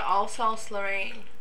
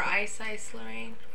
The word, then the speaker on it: Alsace-Lorraine: It's to Alsace-Lorraine, or Ice-Ice-Lorraine. I've heard it pronounced both ways.